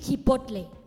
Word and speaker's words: Chipotle: It is Chipotle.